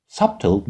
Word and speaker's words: subtle: Subtle Means for Information Transfer subtle means حالا بیش رو هم میتونید تلفظ نکنید بر اساس بعضی تلفظ ها